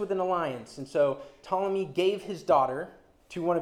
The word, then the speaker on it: Ptolemy: And the general in the south, called Ptolemy, which for those of you taking notes is spelled with a silent P at the beginning, so keep that in mind. Ptolemy would reign over Egypt.